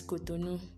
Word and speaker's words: Cotonou: Cotonou, like we used to pronounce it here, Cotonou. The French pronunciation is Cotonou.